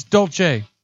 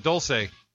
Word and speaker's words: Dolce: It's spelled D-O-L-C-E. I've been there for the Cronuts. So it's not like I don't know where it's at, but I'm just not pronouncing it right.